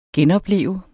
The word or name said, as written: genopleve